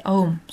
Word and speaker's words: Aum: Birlikte aum diye telaffuz edilen üç harf a, u ve m tüm olası seslerin genel sembolleridir.